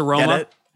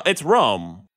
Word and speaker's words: Rome: How do you pronounce it? It's just spelled like Rome, like the city. – Is it? I thought there was an A at the end. – No, R-O-M-E. It's Rome. – Oh, okay. – Yeah, it's Rome.